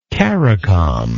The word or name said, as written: CARICOM